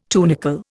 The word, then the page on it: tunicle: Indian